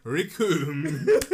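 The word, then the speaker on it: Recoome: Raccoon. It rhymes with doom. – It's like he's here in the room with us. Everyone, Raccoon.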